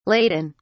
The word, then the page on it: ladin: British English